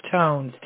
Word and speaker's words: Tones: Oh, my last name is Tones, T-O-N-E-S. – T-O-N-E-S. – But my first name... Yes, like ringtones.